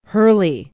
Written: Hurley

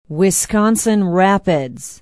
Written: Wisconsin Rapids

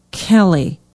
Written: kelly

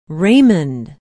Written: raymond

